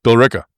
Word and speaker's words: Billerica: And actually, the biggest chunk is going to Medtronic, nearly $5 million for a expansion in, I hope I pronounce this right, Balearica. – Nope. – No. – Try again. What? No, I won't even make you do that. Billerica. – Billerica. Wow. I thought Minnesota had some bad pronunciation. – No, we like to mangle things up here as well. – That's Billerica. Fantastic.